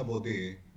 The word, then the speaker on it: Baudet: Ciao, il mio cognome si pronuncia Baudet, mio nonno era francese.